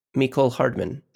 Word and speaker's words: Mecole Hardman: Mecole Hardman.